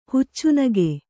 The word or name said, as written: ಹುಚ್ಚುನಗೆ